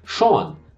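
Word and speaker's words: Sjón: Então, o co-escritor, um dos roteiristas desse filme, é o habitual letrista, parceiro, poeta islandês Sean, né, que se escreve S-J-O-N, Sean.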